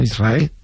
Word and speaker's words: Israel: Israel, ¿no? – Y la S con la R es difícil de pronunciar. La gente dice Israel, sin que suene la S.